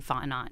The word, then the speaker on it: Fontenot: I kept wanting to say Fontenot, but it's not that. The T is silent, right, unlike in Taryn.